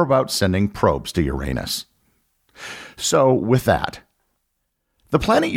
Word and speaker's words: Uranus: If you poll astronomers, they will almost universally tell you that the name of the seventh planet is Uranus. Likewise, if you check various dictionaries and pronunciation guides, they will also confirm that Uranus is how the planet is pronounced. Therefore, for the remainder of this episode, that is what I will be calling it, even though I know many of you probably pronounce it Uranus. The Uranus pronunciation, of course, generates tons of giggles and puns.